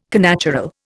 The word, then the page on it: connatural: Indian